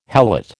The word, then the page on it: helot: American English